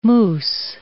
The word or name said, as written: mousse